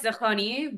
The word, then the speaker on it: Dehghani: Um, it's pronounced Dehani, but everyone just says Degani.